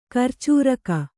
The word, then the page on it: ಕರ್ಚೂರಕ: karcūraka